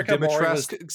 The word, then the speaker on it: Dimitrescu: Chris, it's pronounced Dimitrescu. Say it right.